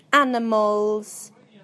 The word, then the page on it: animals: British English